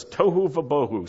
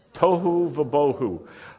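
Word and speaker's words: tohu vavohu: Can you say that? tohu bohu. If you don't say it right, you're just going to end up with tofu, which this is not at all what it's describing. The earth was tohu vabohu.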